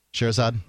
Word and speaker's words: Scheherazade: Scheherazade.